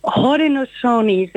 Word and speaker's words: Haudenosaunee: De inheemse makers, ja, ik heb er wat van gemaakt, maar hoe spreek je dat uit? – Haudenosaunee, zeg je. – Dus, het is met een G, dus ja, ik dacht het al.